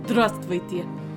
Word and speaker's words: Здравствуйте: Здравствуйте. – Oh, further. – Здравствуйте. – It sounds like stress feeding.